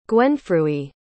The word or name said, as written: gwenfrewi